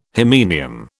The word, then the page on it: hymenium: British English